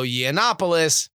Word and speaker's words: Yiannopoulos: Yiannopoulos,